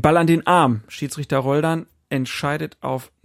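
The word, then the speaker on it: Roldán: Roldan, vielleicht Betonung auf der zweiten Silbe, das mag sein, ja.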